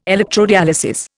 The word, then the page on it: electrodialysis: Indian